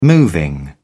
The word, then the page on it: moving: British English